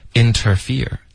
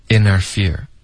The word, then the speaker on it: interfere: 可是后面呢 interfere center 这两个字呢，在美式发音中特的音几乎肯定会省略 interfere center，因为这个舌尖顶上颚发 n，再爆一个 t 有点别扭，就直接连发 n 过去了。